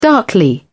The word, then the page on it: darkly: British English